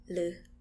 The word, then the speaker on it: หรือ: หรือ So you actually say it with an L. – Yeah, with the L sound.